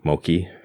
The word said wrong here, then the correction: mochi: I don't know how you pronounce that. – Wonderful. It's mochi. Yeah.